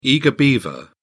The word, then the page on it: eager beaver: British English